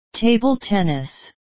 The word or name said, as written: table tennis